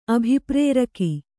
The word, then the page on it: ಅಭಿಪ್ರೇರಕ: abhiprēraka